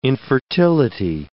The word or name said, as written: infertility